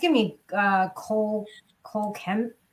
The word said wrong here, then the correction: Kmet: How do I say that? – Kmet, Cole Kmet. Kmet.